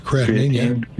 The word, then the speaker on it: creatinine: Creatinine.